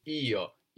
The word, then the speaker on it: io: And this is io, io. And it can sound a little bit more like yo, yo, when it's, especially when it's pronounced in a sentence, a little bit like the English A, no?